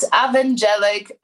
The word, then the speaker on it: Avangelic: At Avangelic on pretty much every platform. It is spelled A-V-A-N-G-E-L-I-C. But it's your girl, Ava Like Lava. First name, Ava. The rest of it says like lava.